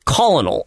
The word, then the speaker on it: colonel: That's colonel, if you are bad at spelling and pronunciation.